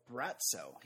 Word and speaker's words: Braco: His third consonant is pronounced with a TS sound. Oh, Bratzo. He's called Bratzo.